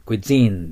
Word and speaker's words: cuisine: cuisine です。 cuisine じゃないです。 G の発音じゃなくて Z の発音です。スが濁るんです。 Z、ス、ス、 Z ですから。